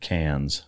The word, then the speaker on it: Cairns: Plus, we were up in Darwin and up in the Northern Territory, and the reason that I ended up in I know you guys pronounce it Cairns, which it's spelled Cairns, but you know, I guess that's the accent difference.